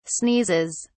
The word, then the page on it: sneezes: British English